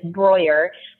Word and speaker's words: Breuer: And if you want to be like correct about it, it's Breuer, which rhymes with lawyer. So that's what I tell people, to kind of like put it like Breuer rhymes with lawyer. So Mary Breuer Photos is my Instagram handle.